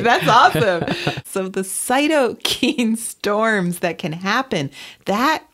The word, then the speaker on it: cytokine: It's that cytokines.